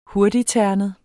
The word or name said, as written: hurtigternet